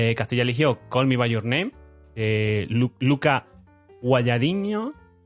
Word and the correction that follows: Guadagnino: Yo, como, como, creo que tengo la mayor potestad de los tres para decir cómo se pronuncia esto, porque vengo de Sicilia y, he hablado con este hombre. guadagnino, vamos a dejarlo así.